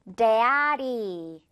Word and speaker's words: daddy: d가 아니라 지금 이 중간에 있는 d 소리는 양옆에 모음 소리들 사이에 이렇게 쏙 껴있기 때문에 시냇물 흐릅니다. 그래서 Daddy, Daddy.